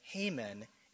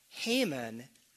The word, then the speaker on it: Haman: In fact, Haman's name sounds almost identical and is spelled almost identical to the Hebrew noun for wrath or anger, the old English word wrath, starting with a W, R-A-T-H. So we know how to pronounce Haman's name.